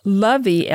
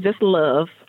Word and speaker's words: LOVEE: In that, Lovey, L-O-V-E-E, Love, Lovey, stands for? – Ladies of Virtue Eclectically Excelling. So the last E is silent, so it's really, it's just love, but the extra E, it is L-O-V-E-E.